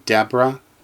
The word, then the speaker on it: Deborah: Like the English name Deborah, which has an H at the end, that H is silent because Deborah is a Hebrew name.